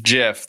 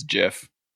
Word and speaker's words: GIF: GIF. The guys who started it said it's GIF.